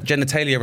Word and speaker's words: genitalia: Genitalia rhymes with Australia.